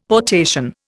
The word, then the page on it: potation: Indian